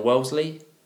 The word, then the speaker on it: Wellesley: Wellesley. I said that right? – Yeah,